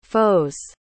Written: foes

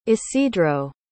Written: isidro

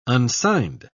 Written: unsigned

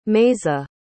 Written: maisa

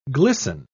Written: glisten

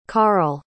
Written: karl

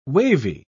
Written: wavy

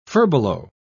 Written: furbelow

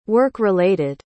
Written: work-related

